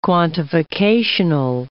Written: quantificational

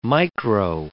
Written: micro

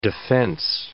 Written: defense

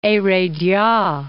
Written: Heredia

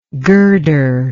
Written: girderless

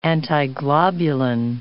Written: antiglobulin

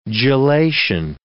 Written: gelation

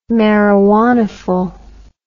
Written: marijuanaful

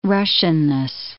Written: Russianness

